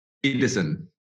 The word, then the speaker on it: Pedersen: So it's Benjamin Pedersen.